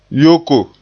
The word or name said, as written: yoko